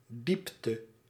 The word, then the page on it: diepte: /di:p.tə/